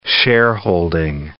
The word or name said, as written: shareholding